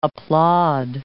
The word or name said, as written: applaud